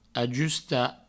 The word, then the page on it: ajustar: ajusta